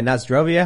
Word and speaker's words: Na zdrowie: Na Zdrawi? – Nazdrowie. Oh yeah, Nazdrovia. How do you say that? Na zdrowie.